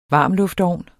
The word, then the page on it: varmluftovn: [ ˈvɑˀm- ]; [ ˈvɑːm- ]